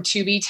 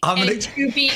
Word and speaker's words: Tubi: Tubi or not to be. – There you go. – Brilliant. It rhymes with free. Tubi is free.